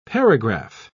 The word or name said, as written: paragraph